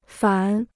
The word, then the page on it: 反: fǎn